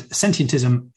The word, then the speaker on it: sentientism: And sentientism, you got the pronunciation perfectly. It is just the word sentient with ism on the end.